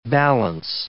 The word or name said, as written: balance